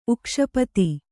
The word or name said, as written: ಉಕ್ಷಪತಿ